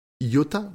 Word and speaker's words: Iota: En chemin, Iota, je vais le prononcer à la française celui-là, embête Amy et Charlie le, et, lui demande d'arrêter, et Charlie lui demande d'arrêter de le faire chier.